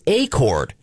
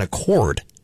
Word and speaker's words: Acord: It's Acord, like acorn, but Acord. Not like the model of the Honda sedan, which is an Accord, or an agreement that you come with somebody.